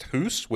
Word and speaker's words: Hus: Same thing with Jan Hus, which I guess is pronounced Hoos, which rhymes with goose.